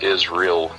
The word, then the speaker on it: Israel: A lot of people think it's Luke Israel, but, you know, growing up, I guess, in the South, it's my dad always pronounced it as Israel, you know, with, like, the hard A.